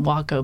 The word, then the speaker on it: Waco: But Weaver Aircraft Company decided to make the pronunciation Waco based on that Indian war cry.